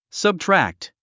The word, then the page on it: subtract: səbtrǽkt; サブトラァクト